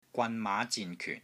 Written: GWAN3 MAA5 ZIN3 KYUN4

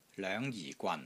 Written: LOENG5 JI4 GWAN3

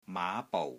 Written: MAA5 BOU6

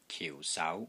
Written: KIU4 SAU2

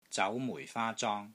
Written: ZAU2 MUI4 FAA1 ZONG1

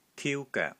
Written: TIU1 GOEK3

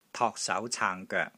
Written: TOK3 SAU2 CAANG3 GOEK3

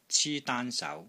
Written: CI1 DAAN1 SAU2

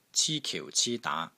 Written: CI1 KIU4 CI1 DAA2

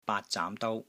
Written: BAAT3 ZAAM2 DOU1

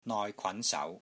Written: NOI6 KWAN2 SAU2

